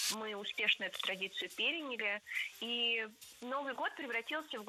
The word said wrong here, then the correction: переняли: Переняли. И это тоже ошибка. Правильный вариант – переняли. Ударение ставим на третий слог.